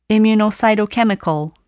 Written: immunocytochemical

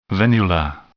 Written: venular

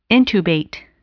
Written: intubate

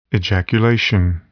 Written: ejaculation

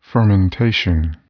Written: fermentation

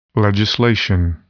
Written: legislation

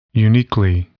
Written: uniquely

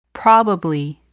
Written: probably

